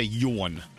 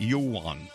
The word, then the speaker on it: yawn: You're giving it two syllables. – Yeah. – You're calling it yawn. – Yawn. Yawn. – It's yawn. Yawning. Isn't it yawn? – Yes. Like dawn.